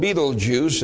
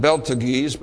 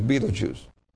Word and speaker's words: Betelgeuse: Then you have another star on the shoulder, and that star is called Betelgeuse, is the way it's pronounced. Most of you would probably pronounce it Beltegeuse because that's the way it looks. But the actual pronunciation is Betelgeuse.